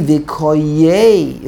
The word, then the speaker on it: Vikoyei: That the correct pronunciation in Sefer Yeshaya should be Vikoyei. It should be Vikoyei.